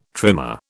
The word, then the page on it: trimer: British English